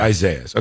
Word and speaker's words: Isaias: Isaias, is that the right way to say this? We got a state of emergency in the state of Florida for Isaias. So that's right, Josh, you said? – Yeah.